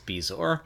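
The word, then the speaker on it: bezoar: We learned how to say Bezor. Bezor? – Yes, Bezor.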